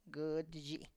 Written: gId zhi

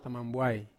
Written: tamambuai

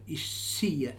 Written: Esia

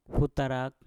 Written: putarak

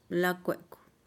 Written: lakuekuh

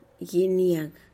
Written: giniag

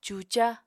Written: cuca

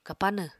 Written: kapanw

